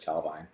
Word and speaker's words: Calvine: The Calvine UFO, Calvine, people pronounce it different ways. Nick said it's Calvine.